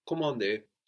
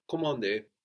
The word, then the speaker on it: commander: Commander. So again, the N here that we have at the end of the syllable is kind of silent, no? Commander.